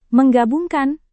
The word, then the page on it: menggabungkan: Indonesian